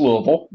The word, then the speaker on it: Louisville: It's pronounced Louisville, but yes, you are absolutely correct.